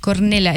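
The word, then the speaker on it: Cornellà: El Cornelá. – Híjole. – Cornelá, es que es en catalán. – Ya. – Unión Deportiva, Cornelá, con acento en la...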